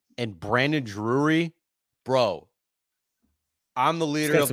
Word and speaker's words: Drury: How do you say it? Drury? – Drury. Brandon Drury.